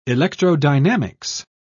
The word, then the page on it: electrodynamics: British English